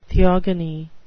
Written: theogony

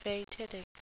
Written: fatidic

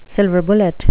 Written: silver bullet

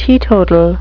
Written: teetotal

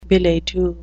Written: billet-doux

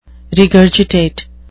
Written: regurgitate

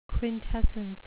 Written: quintessence

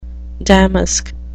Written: damask

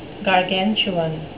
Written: gargantuan

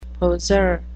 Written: poseur

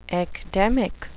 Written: ecdemic

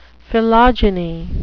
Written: philogyny

